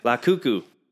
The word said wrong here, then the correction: Lukaku: Lukaku. That's his name. Lukaku.